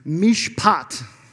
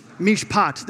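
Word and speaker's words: Mishpat (מִשְׁפָּט): Mishpat. Mishpat. There you go. Mishpat. That's what it looks like. It's the Hebrew word for justice. Mishpat shows up all over the place in the Old Testament.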